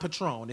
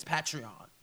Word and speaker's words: Patreon: Mama is Patreon. I don't even remember mama, but it's not Patron. It's Patreon. Yes.